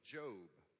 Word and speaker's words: Job: So open up your Bibles to the book of Job. If you're unfamiliar where Job is, it's spelled J-O-B, but it's pronounced differently, but it's before the book of Psalms.